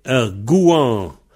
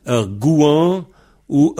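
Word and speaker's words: gouañv: Et alors, le contraire de han, c'est gouan. Gouan, G-O-U-A-N tilde V. Pareil, le V, on le prononce W ou on le prononce pas. Gouan ou gouan-ou. Gouan, gouan-ou. G-O-U A, N, tilde, V.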